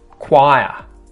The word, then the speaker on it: choir: Choir. Choir. Choir. Choir. I don't know why this one's spelt with a CH, guys.